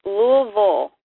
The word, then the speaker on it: Louisville: How do we say it? – It's Louisville. – Louisville. Louisville. – Yeah, it's like the I isn't there. It's a U.